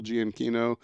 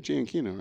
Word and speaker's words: Giacchino: I know a lot of people have said, well, maybe Michael Giacchino. But did I say his name right? Giacchino, right? – Or Giacchino. – Yeah. I think so.